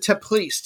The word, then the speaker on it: Teplice: Bet on, I'm going to pronounce it Teplice, T-E-P-L-I-C-E.